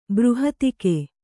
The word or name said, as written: ಬೃಹತಿಕೆ